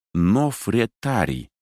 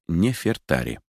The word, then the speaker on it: Нефертари: Так, в середине XX века немецкие египтологи давали огласовку имени царицы как Нофретари, однако теперь оно читается как Нефертари.